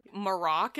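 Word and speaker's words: Marrok: Maroc is how he pronounces it in the show, but you can pronounce it however you want.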